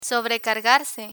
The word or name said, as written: sobrecargarse